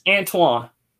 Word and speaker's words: Antoine: It's Antoine.